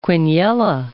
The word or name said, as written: quiniela